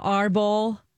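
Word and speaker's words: árbol: So anything like ancho, arbol. Am I saying that right, Alexis? – Uh-huh. Yep.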